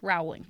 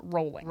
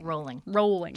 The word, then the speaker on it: Rowling: And that's another one where if you look at it, you might go, oh, maybe her name is Rowling. Nope. She pronounced it Rowling. – Rowling.